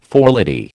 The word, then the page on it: forelady: American English